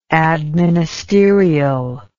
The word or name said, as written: administerial